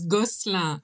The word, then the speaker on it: Gosselin: So my married name is Gosselin and my Indian surname is Uchgavkar.